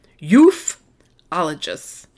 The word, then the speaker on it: ufologists: Okay, but according to ufologists, what I like their pronunciation spelled out, pronounced ufologists. Y-O-O-F.